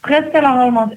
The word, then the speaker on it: Priscilla Holmans: Holmans, Priscilla Holmans, zeg ik dat goed of niet? Is dat jouw artiestennaam? Of, Priscilla Holmans is mijn naam, inderdaad. Artiestennaam en geboren naam.